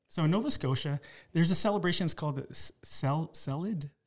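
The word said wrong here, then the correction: ceilidh: So anyways, it's a kitchen party. So I butchered it. It's C-E-I-L-I-D-H. – Oh, a Kaylee. – Is that Kaylee? – Yeah, I know.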